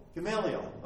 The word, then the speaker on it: Gamaliel: Is that how you pronounce it? – Gamaliel. – All right, there we go.